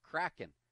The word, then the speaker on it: Kraken: This Kraken, I thought it was Kraken. It's a mythological fish that's pronounced Kraken.